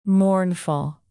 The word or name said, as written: mournful